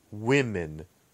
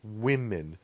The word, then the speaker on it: women: So, man, men, kind of, you know, not very hard to understand, but pay attention to woman, woman, and women, women. So even though the spelling doesn't change in the plural version of the word women, the pronunciation definitely changes. So it's one woman, woman, and two women.